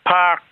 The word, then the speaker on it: Parke: How do you say it again? – Park, P-A-R-K-E.